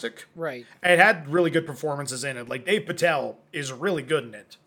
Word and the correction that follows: Dev Patel: Dave Patel. Or Dev Patel. Not Dave Patel.